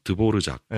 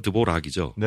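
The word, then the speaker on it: Dvořák: 체코의 유명한 작곡가, 드보르작. – 네. – 드보르작. – 아~ 사실은 영어로 하면 드보락이죠. – 네네. – 그 R 발음이 르르 이렇게 나가지고.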